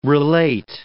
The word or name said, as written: relate